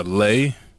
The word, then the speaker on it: Lea: Lay. – How you spell it? – L-E-A.